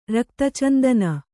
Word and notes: ರಕ್ತಚಂದನ: rakta candana

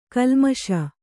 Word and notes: ಗಲ್ಲಿಮೀಸೆ: galli mīse

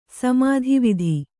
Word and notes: ಸಮತಾವಾದ: samatāvāda

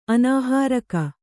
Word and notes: ಅನಾಹಾರಕ: anāhāraka

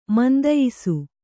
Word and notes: ಮಂದಯ್ಸು: mandaysu

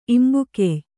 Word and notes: ಇಂಬುಗೆಯ್: imbugey

